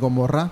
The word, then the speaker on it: Gomorrah: Going back to the Bible in the Old Testament, uh, when God decided to destroy um, Sodom and Gomorrah.